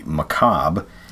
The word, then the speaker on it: macabre: I believe this is pronounced the macabre.